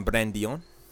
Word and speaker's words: Brandeon: And I'm Brandion. – Brandion? You're Brandion today. Yeah, Brandion today. – Spell it. B-R-A-N-D-E-O-N.